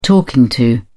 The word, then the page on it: talking to: British English